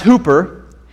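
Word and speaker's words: hooper (ὑπέρ): In the Greek, it's pronounced hooper, and it means above and beyond and so much more than what you expect.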